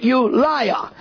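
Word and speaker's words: Ulai: It's spelt U-L-A-I. I would say pronounce it Ulia. Some might otherwise.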